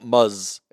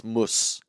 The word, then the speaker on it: Muslim: You pronounce the s, not the z. It's not muzz. It's mus.